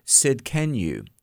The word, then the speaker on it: Tsidkenu: Well, and one of those names is Yahweh Tsidkenu. Now, this the T is silent.